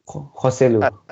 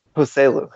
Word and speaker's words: Joselu: Is it Joselu? Yosulu? I'm not... – Joselu. – Joselu. There we go. I was way off.